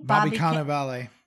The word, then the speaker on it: Cannavale: Bobby Cannavale.